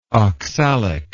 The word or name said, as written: oxalic